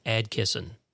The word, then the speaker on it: Adkisson: Yes, that's how you pronounce that last name. You'll probably hear Atkisson, Atkinson. No. It is A-D-K-I-S-S-O-N, Atkisson. That is the proper way to spell that last name. Everybody else is wrong.